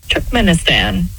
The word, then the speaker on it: Turkmenistan: Turkmenistan.